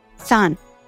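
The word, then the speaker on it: सान (さん): रोमा जी में सान की स्पेलिंग है S A N N for Nagpur इसकी प्रोनाउंसिएशन फिर से रिपीट करती हूँ सान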